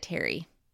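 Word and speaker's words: Thierry: Terry. – Okay. – Mm-hmm. That I had to, like... – Listen hard. – Because it's written, like, theory, but Brad Pitt pronounces it Terry.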